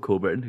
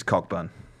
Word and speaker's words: Cockburn: We all know someone called Coburn who gets called Cockburn. – Do we? – Yeah. – I never understood why it's pronounced like that. Because phonetically it is Cockburn.